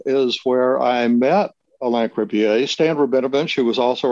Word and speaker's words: Cribier: and Alain Cribier. Am I saying his name correctly? – Cribier, yes.